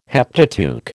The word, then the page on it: Heptateuch: American English